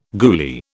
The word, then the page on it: goolie: British English